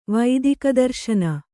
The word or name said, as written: ವೈದಿಕದರ್ಶನ